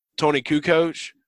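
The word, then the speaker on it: Toni Kukoč: Tony Kukoc, that they played against.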